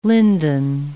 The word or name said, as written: linden